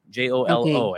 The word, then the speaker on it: Jolo: J O L O.